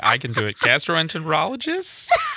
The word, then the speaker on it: gastroenterologist: But anyway, I was going to the gastroenterologist. How do we pronounce it? I was got to ask my white man what the gastroenterologist. See, I can't even do it though.